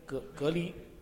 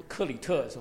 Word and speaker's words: 克里特: 然后呢第一章呢我们今天不会讲太跟大家分享太多呢，是讲到保罗把提多留在这个 Crete 怎么念隔离不克里特是吧，克里特 OK 这个就是那边是希腊的最大的一个岛，所以挺大的一个区域，所以说在他把提多留在那个岛上，在各个城市要设立长老。